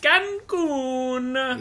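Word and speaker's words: Cancún: But hey, you've got one more week with me, and then we're heading off to sunny Cancun. – Is that how they say it? – Cancun.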